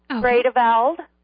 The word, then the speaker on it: Vredeveld: So, My name is Betty Vredeveld. – Could you spell that, please? – V-R-E-D-E-V-E-L-D.